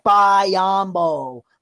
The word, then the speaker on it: Biyombo: I love it when they put the huge emphasis on the bye. Like, if you listen to a game and they're like, Bismack Biyombo.